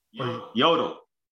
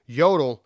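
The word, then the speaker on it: Jodl: A man by the name of General Alfred Jodi, or Jodi. – Yodel. – Yodel. – Okay. Yodel. The J is silent. – Yeah, that's an L, not an I. Yodel.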